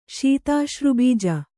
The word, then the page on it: ಶೀತಾಶ್ರುಬೀಜ: śitāśru bīja